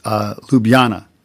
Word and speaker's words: Ljubljana: I guess in Croatian, when you have a J, like Ljubljana, the city, I think it's the capital city, it'd be Ljubljana. And that's how the J is a Y.